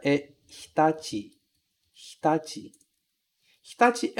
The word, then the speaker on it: Hitachi: Um cuidado que a gente tem que ter é de pronunciar bem o H, hi. Esse CHI no final ser pronunciado ti, hitachi. E veja que quando eu pronuncio hitachi, eu não falo hitachi, hitachi. Esse I aqui, ele quase some, né? Ele fica quase mudo. Eu falo assim, ó, hitachi, hitachi.